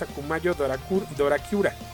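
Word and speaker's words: Akumayo Dorakura (悪魔城ドラキュラ): Eh, bueno, originalmente Castlevania no se llama Castlevania en Asia, se llama Akumaju, Akumayu Drácula, eh, o mejor este pronunciado es Akumayo Dorakura es el, nombre correcto de esta saga.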